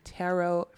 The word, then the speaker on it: tarot: First of all, I kept saying tarot. I'm from the South. You can definitely. – Oh, that's okay, girl. – Wait, that's how you say it? You can say it like that too? – Yeah. Tarot. – That is honestly hilarious. – Well, I was correcting her. I thought it was only tarot. – Oh, it's okay. It's okay. No, I've definitely heard people say tarot.